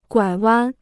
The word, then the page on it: 拐弯: guǎi wān